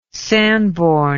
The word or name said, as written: Sanborn